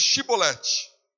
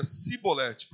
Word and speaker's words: Shibolete: Então ele dizia: dize, pois, Shibolete. Porém ele dizia Sibolete, porque não podiam pronunciar assim bem.